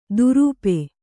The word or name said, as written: ದುರೂಪೆ